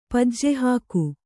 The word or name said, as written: ಪಜ್ಜೆಹಾಕು